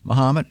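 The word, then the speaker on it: Momence: Muhammad, as in Ali, at Yellow & Company.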